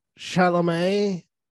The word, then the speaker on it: Chalamet: Taylor Russell, Andre Holland, Mark Rylance, Timothy Chalamet.